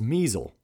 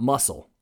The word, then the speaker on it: Muessel: While this is true, I pronounce the brewery as the Muscle Brewing Company, whereas it is actually the Muessel Brewing Company. So it's spelled M U E S S E L, but that is measle, not muscle.